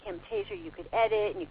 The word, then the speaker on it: Camtasia: Camtasia. – Can you spell that? – C-A-M. – Oh, C-A-M, Camtasia, okay.